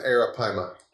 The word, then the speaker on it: arapaima: And I don't even know how to say the name of this monster fish. – I do. – Okay, tell me. – It's an arapaima.